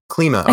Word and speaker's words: Klima: K-L-I-M-A. – And Klima. – Klima. – I think it's pronounced Klima, yeah. – I believe you. Klima, K-L-I-M-A.